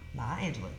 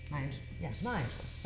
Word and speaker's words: Maya Angelou: Maya Angelou. Maya Angelou. That's how you say yeah. – it. – Yeah, that's how you pronounce it.